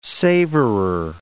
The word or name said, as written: savorer